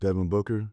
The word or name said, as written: Devin Booker